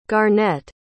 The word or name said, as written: garnett